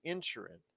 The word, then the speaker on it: Inisherin: All Quiet on the Western Front, Avatar, The Way of Water, The Banshees of, I know it's spelled Inisherin, but they pronounce it in the movie Inisherin.